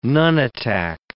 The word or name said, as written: nunatak